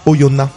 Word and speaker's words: Oyonnax: je me permets de te reprendre, on dit Oyonna. On ne prononce pas le oui.